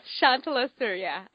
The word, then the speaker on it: Shantala Surya: Yes, Shantala Surya.